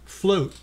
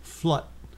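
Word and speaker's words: Flotte: It's in America, it's pronounced float. In France and Germany, it's pronounced flut. Because it's F-L-O-T-T-E.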